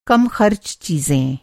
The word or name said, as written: कम-ख़र्च